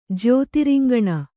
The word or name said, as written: ಜ್ಯೋತಿರಿಂಗಣ